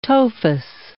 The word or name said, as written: tophus